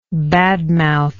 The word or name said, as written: badmouther